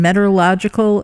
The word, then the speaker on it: metallurgical: Your degree is is in uh, chemical in metallurgical engineering.